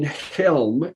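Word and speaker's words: Chełm: It was the big Baptist church in Helm, and again, that's spelled Chelm.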